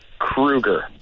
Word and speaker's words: Kruger: Maybe people are afraid to ask for some reason that I couldn't understand, but no, the last name pronunciation is Kruger. – Kruger. – There you go. – Yes. – That's amazing. – It's a German variant.